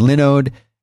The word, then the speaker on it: Linode: And Linode is spelled L-I-N-O-D-E, like Linux, not Linux.